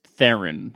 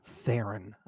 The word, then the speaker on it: Theron: It's Theron, I think. – Oh, is it Theron? It's not Baron? – I think it's Theron. – Theron. Okay. Well, I got another name wrong. Add it to the list. – No, it's a tough one. I'm pretty sure it's Theron. – Okay. I guess the TH sounded a little like a B.